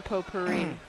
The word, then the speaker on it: potpourri: Now you don't pronounce the T in potpourri. – What do you mean I don't pronounce it? – I mean, you just did, but you're not supposed to. – How's it pronounced? – Potpourri.